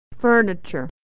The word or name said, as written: furniture